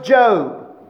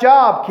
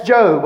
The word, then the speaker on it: Job: "There was a man in the land of Uz whose name was Job." I know it looks like Job, kids, but that's Job, okay? Just see, it's spelt the same, but you pronounce it Job, okay?